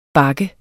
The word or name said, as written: bak